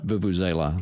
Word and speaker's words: vuvuzela: I can't even pronounce that. What, Vuvuzela? – Vuvuzela. – Vuvuzela? – Yeah.